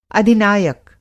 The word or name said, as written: अधिनायक